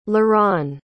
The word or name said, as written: laron